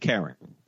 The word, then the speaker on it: Kering: I always pronounce it Kering. K-E-R-I-N-G.